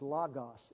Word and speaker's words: logos: The Greek word is logos. These are omicrons, not omegas. And omicron in Greek is pronounced like omelette.